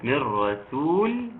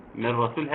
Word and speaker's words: من رسول: من را من رسول، واتفقنا ناخد بالنا منها جيدا من را علشان ما تبقاش إيه تبقى ما تطلعش من را كما لو كان فيها غنة، لا من را على طول. فننطقها إزاي؟ بننطقها من رسول من رسول هكذا من رسول.